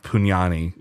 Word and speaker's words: Pugnani: It's got to be pronounced Pugnani, which is not great for me.